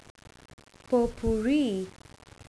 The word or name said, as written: pot pourri